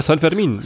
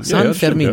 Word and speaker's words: San Fermín: Ja, San Fermín. – San Fermín, stimmt.